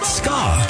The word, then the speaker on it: ska: The style is pronounced ska.